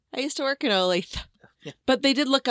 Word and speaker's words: Olathe: Yeah, it's pronounced Olathe, if you're curious.